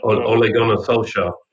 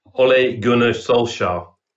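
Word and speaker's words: Ole Gunnar Solskjær: But Ole Gunnar Solskjaer. – All right, one more time. – Ole Gunnar Solskjaer.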